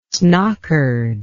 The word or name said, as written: snockered